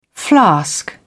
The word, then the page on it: flask: British English